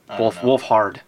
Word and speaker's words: Wolfhard: Yeah, Finn Wolfgard from, or Wolf, how do you pronounce it? Wolfhard. Finn Wolfhard.